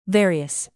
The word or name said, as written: various